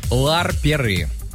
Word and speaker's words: ларперы: Оно произносится как ларперы.